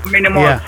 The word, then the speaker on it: Mini Mos: So I just combined the two, Mini Mos, you know. Ooh, and wow. Back then I was still in love with Rick Ross, so I wanted my name to rhyme with that.